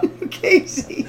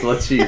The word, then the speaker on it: Lucchese: Lucchese. – Lucchese, crime family soldier. – Isn't that Lucchese? – Yes, Lucchese.